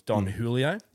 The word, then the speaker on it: Don Julio: Now, it was Haley that was one that originally sent me a text and say that I've been saying it wrong the whole time. It's Don Julio.